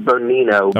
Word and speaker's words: Bonino: Emma Bonino, how do you say her name? Bonino? – I pronounce it Bonino, okay. – But that might be incorrect. – That's probably right.